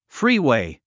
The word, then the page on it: freeway: fríːwèi; フリーウェイ